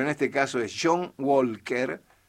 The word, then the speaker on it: Walker: Por eso, Walker. – Y sí, pero saque la L. – Con L. – Sí. Claro, pero sáquela al pronunciar. – Es como walkie-talkie. Walkie-talkie.